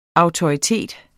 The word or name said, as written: autoritet